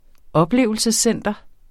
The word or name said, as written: oplevelsescenter